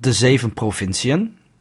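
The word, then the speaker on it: De Zeven Provinciën: I actually do not hate your pronunciation of that. – Oh, thank God. I've been studying up for 2 minutes. – The ship one is called Des Eaux Provinciennes. Which means the seven provinces.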